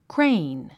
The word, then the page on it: crane: kréin; クレイン